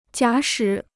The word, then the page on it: 假使: jiǎ shǐ